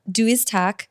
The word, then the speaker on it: Du Iz Tak: So so I want to ask about Du Iz Tak, mm-hmm. – or is that how do you say it? Uh, you said it right.